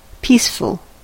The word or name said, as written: peaceful